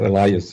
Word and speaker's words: Elias: Norbert Elias, or Elias, if we pronounce it in English,